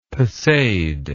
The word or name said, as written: pesade